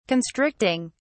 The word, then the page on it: constricting: British English